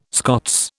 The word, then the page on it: Scots: British English